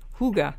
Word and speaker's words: hygge: And don't forget, people, it's spelled, The Hygge Life is the name of the book, and it's spelled H-Y-G-G-E, but you pronounce it in the language Hygge. You know, H-O-O-G-U-H, Hygge, like that.